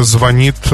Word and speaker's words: звонит: В ближайшем будущем ударение в слове «звонит» может измениться и переместиться на первый слог.